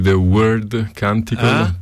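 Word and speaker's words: The Wyrd Canticle: The Wild Canticle, bene, come lo possiamo... – Credo si pronunci The Word Canticle, però perché il word è un concetto che viene dalla mitologia norrena, okay.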